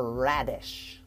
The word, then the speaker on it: radish: All right, now we're going to go to this red little ball, and it's called a radish. A radish. R-A-D-I-S-H. The stressed syllable is the first one.